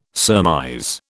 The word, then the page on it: surmise: British English